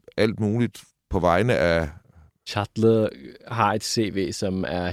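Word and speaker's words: Çatlı: Nu har du fortalt mig, hvordan man udtaler det her navn. Chatle. – Chatle. Ja, Ab- Abdullah Chatle.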